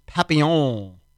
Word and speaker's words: Papillon: The song was called Minute Papillon, which I assume is pronounced Papillon. That's how you pronounce P-A-P-I-L-L-O-N. Papillon.